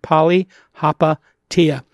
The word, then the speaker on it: Palihapitiya: With me, as always, my co-host, Chamath Palihapitiya, if you don't know how to pronounce it, Palihapitiya.